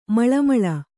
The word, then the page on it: ಮಳಮಳ: maḷa maḷa